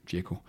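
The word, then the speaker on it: Džeko: For uden ham og så Lautaro Martinez, vi lige har vendt, så er det jo Sanchez og Djeko, øh, nu udtaler jeg det rigtigt, Djeko, som har scoret, det skal vi også lige holde fast i, to mål øh, efter, øh, efter, nytårs, øh, efter nytår.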